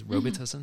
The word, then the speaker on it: Robitussin: Robitussin, mm-hmm.